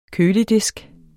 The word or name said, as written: køledisk